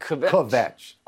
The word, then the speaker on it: kvetch: It's a really nice job you making kvetch into a two-syllable word. – Kvetch. – Like that. That's it.